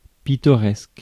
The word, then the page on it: pittoresque: /pi.tɔ.ʁɛsk/; France